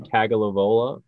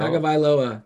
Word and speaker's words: Tagovailoa: Yeah, How do you pronounce the last name? Tagovailoa. Tagovailoa. – Tagovailoa.